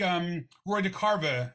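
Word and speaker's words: DeCarava: And I know like Roy DeCarva, am I pronouncing that name right? – Yes.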